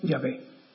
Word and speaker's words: Yahvé: Y el término Jehová, en hebreo, hablando de la pronunciación, no tiene traducción al castellano porque son cuatro consonantes y no tienen sentido de traducción, pero la pronunciación sí, es Yahvé.